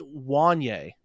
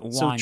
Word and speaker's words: Wanye: His name's actually pronounced Wanye is how the first name is pronounced. – So not Wanya. So tricky one. Wanye. – It looks like it'd be Wanya. It's Wanye. Wanye Morris. So that's actually a fun little wrinkle. I would not have pronounced that Wanye.